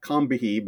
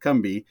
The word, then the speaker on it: Combahee: It's spelled, it should be like Combahee, but the locals there pronounce it Combahee.